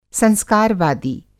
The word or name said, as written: संस्कारवादी